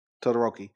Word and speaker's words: Todoroki: Todoroki, I'm sorry.